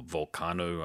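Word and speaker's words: Vulcano: You can see the Sarcophago albums and Volcano or Volcano, I think is the band is pronounced in Portuguese, and Sepultura.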